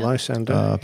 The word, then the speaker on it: Lysander: Yeah, Lysander.